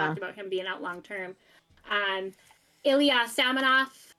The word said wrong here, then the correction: Samsonov: Samsonov, also former Capital goalie. – You are butchering the former Capital goalie's last name.